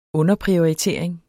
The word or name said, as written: underprioritering